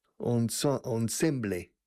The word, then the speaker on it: ensemble: We use the French word ensemble, ensemble, escrito, pronounced more or less in a French way, ensemble.